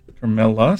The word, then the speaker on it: Carmela: Carmela Soprano. That's right. – Damn. Oh, how do you know that? Huh? – Very close, but you got to pronounce it correct.